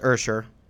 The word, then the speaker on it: Usher: It's pronounced Usher. Thank you.